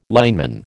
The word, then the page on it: lineman: American English